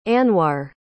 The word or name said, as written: anwar